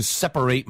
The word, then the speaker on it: separate: Do I have to separate my trash? – 자, 이때 separate는 동사로 발음되기 때문에, 동사로 쓰였기 때문에 발음이 8에요. Separate. 그런데 스펠링은 똑같고요. S-E-P-A-R-A-T이고요. Separate로 발음하시면은 분리된이란 형용사가 됩니다.